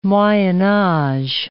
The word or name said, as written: moyen-age